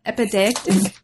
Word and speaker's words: epideictic: You can pronounce epideictic. – Yep, that's how I pronounce it.